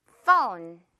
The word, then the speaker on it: phone: phone 발음할 때 그리고 fun이라고 하시는 분들도 봤어요. fun 아니고요. 재밌는 거 아니고요. phone 이렇게 O 발음 끌어주면 돼요.